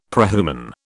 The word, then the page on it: prehuman: British English